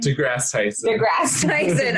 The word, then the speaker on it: deGrasse: deGrasse Tyson. – Every time. – Not like deGrasse, like the team.